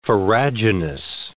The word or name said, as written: farraginous